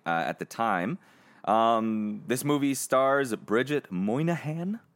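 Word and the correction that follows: Moynahan: Did I say that right? Moynihan? – Okay. Monahan. – Oh, Monahan.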